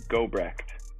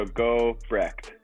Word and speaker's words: Gobrecht: It's kind of tough, actually. I go through this a lot, but it's Gobrecht. – So you say the T. – Yeah, so Gobrecht. It's a German name, so kind of hard to pronounce, but I'm sure you guys will get it.